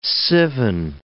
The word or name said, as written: Sivan